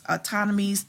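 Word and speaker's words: Ptolemies: I can't remember what I think she was a part of the autonomies, and I know you can't, you don't pronounce the P, but it's autonomies, the autonomies, something like that.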